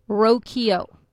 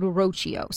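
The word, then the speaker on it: Rocchio: And it is Rokio. – Yes. – And I think I might have said, I know I mispronounced it because when it came up, I kind of drew a blank. I might have said Rokio.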